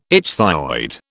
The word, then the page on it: ichthyoid: British English